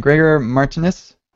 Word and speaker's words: Gregor Martynus: And Gregor Martynus.